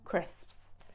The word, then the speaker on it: crisps: hard potato snacks, we call them crisps. Crisps, which a lot of English learners tend to struggle with, the pronunciation of crisps. I hear a lot of crips going on, but crisps.